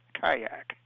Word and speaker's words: KYAQ: public radio station KYAQ, pronounced Kayak, a relative newcomer to the Oregon coast.